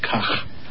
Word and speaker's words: Kach: How do you pronounce that? – Kach. – Kach. Okay.